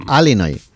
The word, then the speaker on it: Illinois: Se pronuncia Illinois.